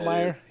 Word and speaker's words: Altmyer: But I do think the what's it, Luke Altmyer, is that his name? Is that how you pronounce that? Luke That is. – Altmyer, he's the quarterback.